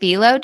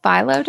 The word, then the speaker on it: phyllo: It's like, is it phyllo dough? Phyllo dough? – Phyllo. Yes. I think. I said that so confidently. I think it's phyllo.